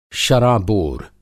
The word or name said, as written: शराबोर